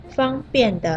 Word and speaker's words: 方便: 那另一个读音“ 方便” 的“ 便” 的意思，大概就是说简单、顺利，没有什么困难。